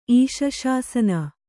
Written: ಈಶಶಾಸನ

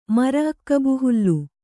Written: ಮರಅಕ್ಕಬುಹುಲ್ಲು

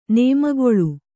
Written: ನೇಮಗಲೆ

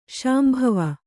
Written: ಶಾಂಭವಿ

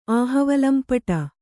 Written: ಆಹವಲಂಪಟ

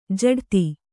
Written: ಜಡಿತ

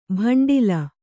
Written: ಭಂಡೀಲ

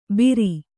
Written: ಬಿರಿ

